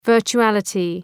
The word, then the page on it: virtuality: ,vɜ:rtʃu:’ælətı